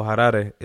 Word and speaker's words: Harare: I was born in in a place called Harare, or Harare is how they'd pronounce it, more like.